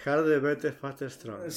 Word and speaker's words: Harder, Better, Faster, Stronger: Harder, Verder. – Faster, Stronger. – Faster and Stronger. Por favor, y mi pronunciación en inglés la vamos a mejorar la próxima. Harder, better, faster, stronger.